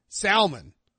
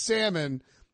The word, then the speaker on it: salmon: And then he's like asking Carr in front of this whole table how to like pronounce salmon. He's like, nah, it's salmon. Is it salmon or salmon? And Carr's like, it's salmon.